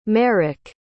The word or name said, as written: merrick